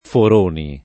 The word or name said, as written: Foroni